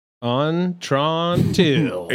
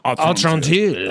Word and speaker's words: Atrantil: Yeah, put a little French on your ah. – Atrantil.